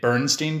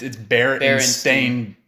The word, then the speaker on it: Berenstain: You know, I'm comfortable sharing that this whole time up of my life up until now, I've been pronouncing it Bernstein Bears. And it's Berenstain. – Yeah, oh, that's right. The A-I-N.